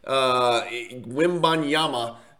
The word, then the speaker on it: Wembanyama: Victor Wembanyama with an M at the end. The last consonant's an M, not an N. He's not Hispanic. Wembanyama.